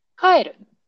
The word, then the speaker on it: 帰る: Please pay attention my pronunciation, pitch accent. 帰る。 This is go home.